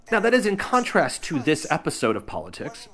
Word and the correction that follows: Poditics: Poditics. Wow, that is, having... Look, this is another... This is actually good. This is good. The name of the show, of course, is Poditics. And that's spelled P-O-D-I-T-I-C-S. It's very close to the word politics, and that was intentional and continues to be intentional. It does make it difficult to say, however.